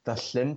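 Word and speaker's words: Llŷn: And then there's Trevor, which is on, I always like pronouncing this because it's Welsh, the Llyn Peninsula. So that's the double L sound in Welsh.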